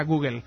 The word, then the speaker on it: Google: De Google, que dicen los ingleses. Google, se pronuncia Google.